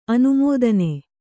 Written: ಅನುಮೋದನೆ

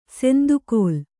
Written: ಸೆಂದುಕೋಲ್

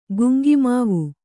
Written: ಗುಂಗಿಮಾವು

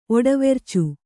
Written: ಒಡವೆರ್ಚು

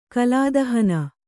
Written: ಕಲಾದಹನ

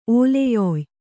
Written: ಓಲೆಯೊಯ್

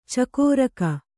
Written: ಚಕ್ರಿಕ

